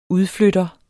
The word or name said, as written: udflytter